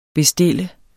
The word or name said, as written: bestille